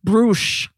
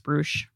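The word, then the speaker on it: Bruush: And I don't know if you remember, but I actually do think, it's pronounced Brush. – Oh, okay. Yeah. Good to know. Well, I mispronounced it, guys.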